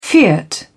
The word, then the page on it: fiat: British English